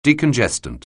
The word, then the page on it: decongestant: anglais